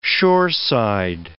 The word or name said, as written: shoreside